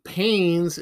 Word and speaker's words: Paynes: Now, Paynes is spelled P-A-Y-N-E-S, not P-A-I-N-E-S.